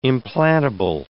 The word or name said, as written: implantable